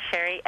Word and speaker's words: Sheri: So it's Sherry, S-H-E-R-I, Winston, W-I-N-S-T-O-N.